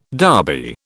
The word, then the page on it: Derby: British English